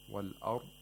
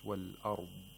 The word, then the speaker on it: والأرض: And it should not be, pronounced like with an abrupt silence. والأرض. والأرض is incorrect. It's والأرض. والأرض.